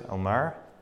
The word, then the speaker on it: Elmira: Anyone know how to say this place? Elmira? Thank you.